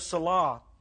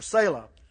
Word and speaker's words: Selah: And then you will run across that word there, Selah, or Selah, some pronounce it.